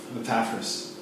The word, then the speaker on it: Epaphras: And I looked it up, that's how it's pronounced. Epaphras.